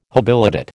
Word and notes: habilitate: American English

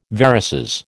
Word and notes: varices: American English

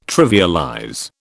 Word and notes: trivialize: British English

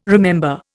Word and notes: remember: Indian

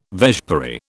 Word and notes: vespiary: British English

